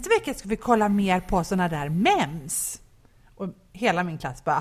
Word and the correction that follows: meme: Och de bara, nej, det heter meme.